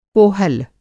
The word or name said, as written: कोहल